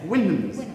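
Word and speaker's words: Houyhnhnms: Huynums. Is that how you say it? – Yes. – Huynums.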